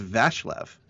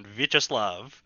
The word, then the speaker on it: Vyacheslav: I think it's pronounced Vashlev. – It's a Vyacheslav. – Then I do not know how to pronounce that first name then. – Speaking as a person who speaks fluent Russian, Vyacheslav.